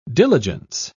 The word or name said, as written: diligence